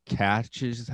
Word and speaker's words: cache: I started, I find these interesting caches. How do you say that? C-A-C-H-E, cache.